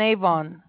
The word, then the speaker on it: Avon: In Cahaba Drive, Southwest, and Avon. – Yes, that's how it's pronounced, yes.